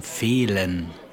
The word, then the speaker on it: fehlen: Most of the time, the silent H comes before L, as in fehlen. That's to lack, to miss. – Spell that for me. – F-E-H-L-E-N, fehlen.